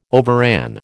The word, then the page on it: overran: American English